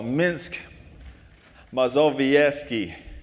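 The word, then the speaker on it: Mińsk Mazowiecki: And she is in close to where this star is at, just east of Warsaw, Minsk, Mazowiecki. How did I do? – Really good.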